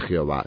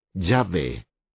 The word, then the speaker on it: Yahvé: J-H-W-H. Como las vocales no se escriben en hebreo, la pronunciación del nombre no es segura, ciertamente no era Jehová, sino más bien Yahvé.